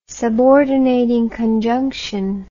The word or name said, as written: subordinating conjunction